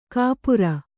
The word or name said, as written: ಕುಪರಿ